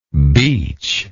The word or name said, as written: beachless